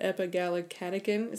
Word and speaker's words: epigallocatechin: And there is a study on that too, and concerns with the, those are so words, that epigallocatechin, is that how you say it? – Yeah.